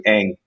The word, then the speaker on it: Ng: His name is Andrew Ng. And Ng is spelled N-G, Andrew N-G.